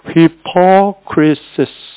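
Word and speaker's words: hypocrisis (ὑπόκρισις): 헬라 발음 한번 해볼게요. hypocrisis. 똑같죠.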